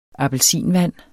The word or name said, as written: appelsinvand